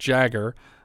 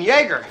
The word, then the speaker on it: Jagger: It's pronounced Jagger. But when I saw his name in the opening credits, I said, "Hey, Dean Jaeger."